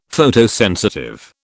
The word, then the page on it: Photosensitive: British English